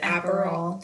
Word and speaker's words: Aperol: Aperol.